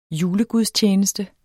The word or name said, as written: julegudstjeneste